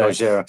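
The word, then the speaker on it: Gojira (ゴジラ): Uh, Japanese, uh, they Japanese, say in Gojira. Japanese people say. – Gojira.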